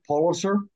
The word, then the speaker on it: Pulitzer: And did I pronounce that right, Pulitzer? – Yes, the Pulitzer Saga.